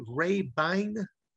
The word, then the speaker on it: Rabine: Today's guest there, we have Mr. Gary, is it, am I going to pronounce it correctly, Rabine? – Perfect.